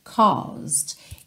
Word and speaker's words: caused: Caused. Essa primeira parte da palavra, essa primeira sílaba, é mais comprida, caused, caused, ok? O E não é pronunciado, então você não vai falar caused, caused, caused.